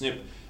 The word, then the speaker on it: SNP: Something's polymorphic in genetics if it varies, but SNP is much easier to say, SNP, pronounced SNP, than single nucleotide polymorphism.